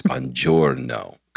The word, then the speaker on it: buongiorno: I am absolutely going to do the effort to say buongiorno, okay, over and over again until I go, oh, the G is silent. It's buongiorno, okay?